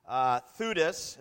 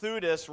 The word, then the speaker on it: Theudas: For before the days, Theudas, is how it's pronounced, Theudas rose up, claiming to be somebody, and a number of men, about 400, joined him.